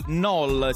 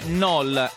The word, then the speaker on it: KNOL: ovvero l'annuncio da parte di Google di KNOL, si scrive K-N-O-L,